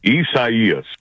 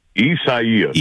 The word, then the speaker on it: Isaias: Isaias. – Wait, one more time. – Isaias.